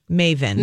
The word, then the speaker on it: Meyvn: When you see the sign, it's pronounced Meyvn. – Meyvn.